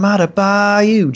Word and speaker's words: Adebayo: but I always think of him in Born on the Bayou, and that's how I say his name, like Bam Adebayo, just like that.